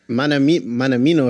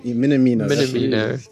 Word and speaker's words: Minamino: Manamino or something. – You're asking. – Minamino.